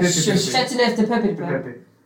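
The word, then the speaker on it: Châteauneuf-du-Pape: In Chateauneuf du Pape, that's not how you say it. Chateauneuf du Pape.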